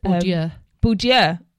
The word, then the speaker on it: Bourdieu: Bourdieu. – Is that how you pronounce it? Oh, okay. Bourdieu.